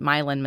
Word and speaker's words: Milan: Milan, which is spelled like Milan, but Milan, Missouri, Paris, Missouri.